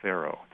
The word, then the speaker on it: Faro: For most of his life, he made his living dealing a very popular game in the 19th century West called Faro. It's spelled F-A-R-O. And it's modeled on the word, you know, the Egyptian king, Pharaoh.